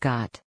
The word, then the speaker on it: khat: khat, pronounced got.